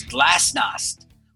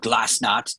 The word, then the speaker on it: glasnost: This was most definitely towards the end of the Cold War as Glasnost, or Glasnost, depending on how you want to pronounce it, was introduced by Soviet Union leader Mikhail Gorbachev, which allowed political expression and dissent within the Soviet Union for the first time.